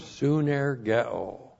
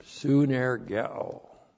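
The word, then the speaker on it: sunergeo (συνεργέω): And then the verb sunergeo. This is a great verb, sunergeo. S-U-N-E-R-G-E, long O, soon ergeo.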